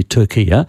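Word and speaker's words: Türkiye: And they wanted the pronunciation changed. And they applied to the US State Department. Both of them agreed that they would do this. Now, I've heard Albanese say Turkiya, mm-hmm. and I've heard Penny Wong say Turkiya.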